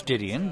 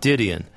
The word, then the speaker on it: Didion: And Joan Didion, am I pronouncing her name right? – I think it's Didion, but... – Didion, yeah.